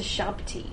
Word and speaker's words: Shabti: Well, I can't remember if wax figures are different to Shabti. – Shabti! I knew I said it wrong, and I said it wrong both times, but you know, that's fine.